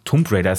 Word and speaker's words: Tomb Raider: Tomb Raider ist richtig, ne? – Tomb Raider, ja. – Tomb spricht man es aus, Tomb Raider mit rein.